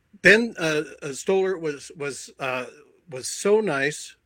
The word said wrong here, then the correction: Stiller: Did I get the name right? – Stiller, yeah. Ben Stiller, sure. – Stiller, I'm sorry.